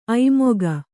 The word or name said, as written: ಐಮುಖ